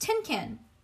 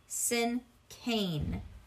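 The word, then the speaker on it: cinquain: "Gee, I'm sorry, May, but it's not tin can. You must have heard me incorrectly. This word is pronounced like the word sin cane."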